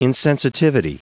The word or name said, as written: insensitivity